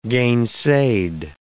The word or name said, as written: gainsaid